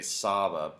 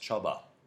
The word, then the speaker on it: Csaba: It's spelled CSABA, which I would say Saba, but it's in Hungarian it's pronounced Chuba.